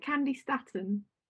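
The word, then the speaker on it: Candi Staton: Candy Staten? – Yeah, that's it.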